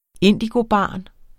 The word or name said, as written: indigobarn